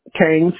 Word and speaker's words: Cain: Kane, C-A-I-N.